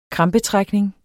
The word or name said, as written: kramper